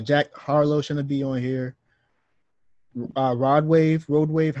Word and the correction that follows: Rod Wave: how you say his name? – Rod Wave.